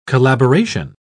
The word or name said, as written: collaboration